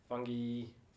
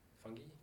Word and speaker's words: fungi: Microbes are made up of bacteria, fungi. What do you guys say? Fungi. – I got fungi. – Fungi. Three different ways of saying it.